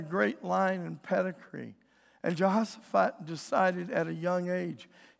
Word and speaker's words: Jehoshaphat: That's not the way you pronounce his name. There's no J's in Hebrew, but that's how we say it. Jehoshaphat.